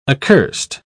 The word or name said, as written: accurst